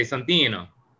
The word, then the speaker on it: Santino: How would you say that? – She usually would say Santino.